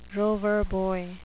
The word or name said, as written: Rover Boy